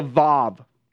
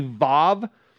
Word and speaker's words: Vav: And so I know your Bible say W-A-W. The W should be a V sound in the Hebrew. It should be Vav. You can pronounce it V-A-V or V-A-H-V, Vav. And so the A is a little bit dragged out.